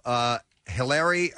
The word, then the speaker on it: Hilarie: Morgan's real-life wife, Jeffrey Dean Morgan's real-life wife, Hilarie, this is how you pronounce her name, H-I-L-A-R-I-E, Hilarie Burton, will play Lucille.